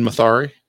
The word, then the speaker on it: Mathare: Mathare. Yeah, and I wasn't going to try to spell it. – Mathare okay. with yes. – an M. – M A T H A R E.